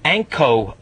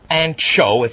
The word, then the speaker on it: Anko: Not Ancho, it's Anko is how it's pronounced, because at one time it was Anchor, and they had to take the R off for legal reasons, so it's Anko.